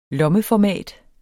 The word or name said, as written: lommeformat